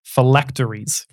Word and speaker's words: phylacteries: I think you pronounce it phylactery. – Phylacteries. – Phylacteries. – I think is the correct pronunciation.